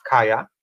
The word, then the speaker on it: Kayah: Uh, so I made friends with, how do you say her name? Kayah? Kayah, Kayah yes.